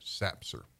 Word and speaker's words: SAPCR: There's an acronym for that, SAPCR, and we pronounce that SAPCR.